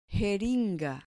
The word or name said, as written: jeringa